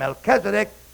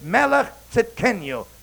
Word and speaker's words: Melchizedek: We call him Melchizedek, but that's a wrong pronunciation. It's Melech Tzidkenu, simply meaning king of righteousness.